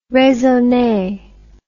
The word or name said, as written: raisonne